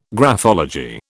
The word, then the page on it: graphology: British English